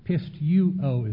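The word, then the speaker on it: Pisteuo (πιστεύω): It's pisteu. Pisteuo. I'll get it right. Pisteuo is how it's pronounced.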